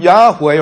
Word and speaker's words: Yahweh: From Yahweh, or YHWH, the Y is also often pronounced as J.